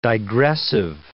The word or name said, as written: digressive